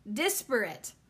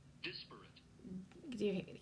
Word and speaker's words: disparate: Disparate. Disparate. – Oh, great. There's two freaking ways, but I definitely was. It's dis, not des. – Disparate.